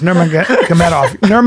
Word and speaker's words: Nurmagomedov: how do you say his name? Is Nurmagomedov. Yeah, that motherfucker takes everybody and wraps him up.